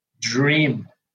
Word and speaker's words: Jream: Se pronuncia dream.